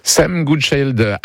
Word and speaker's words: Sam Goodchild: Sam Goodchild à l'anglaise a remporté la première course de la saison Imoca. – Oui, prononcez bien à l'anglaise parce qu'il est franco-britannique. Les Imoca, ce sont ces bateaux à la voile avec des foils capables d'atteindre près de 80 km/h sur l'eau.